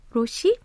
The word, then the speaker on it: Rothy: First up is the K-pop song Bunning by Rothy, Korean pronunciation Roshi.